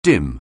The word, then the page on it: dim: anglais